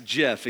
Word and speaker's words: GIF: And the right pronunciation is? Yes, JIF, exactly.